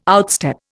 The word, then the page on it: outstep: Indian